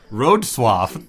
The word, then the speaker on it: Wrocław: In English, it is said as Wrocław. – What? – Road swaff.